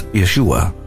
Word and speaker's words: Yeshua: Then, of course, in the New Testament, Jesus is referred to as Yeshua, which is how his name would have been pronounced by his mother and his family and everyone who knew him when he was walking upon the earth.